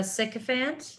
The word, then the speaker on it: sycophant: I just looked up sycophant. You did pronounce it correctly.